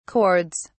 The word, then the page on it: chords: British English